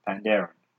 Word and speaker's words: Pandaren: Yeah, but you said it wrong. – Wait, how do you say the World of Warcraft phrase? – Pandaren. – What did I say? – Pandering. – No, I also said Pandaren.